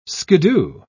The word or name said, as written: skiddoo